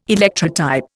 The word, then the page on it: Electrotype: Indian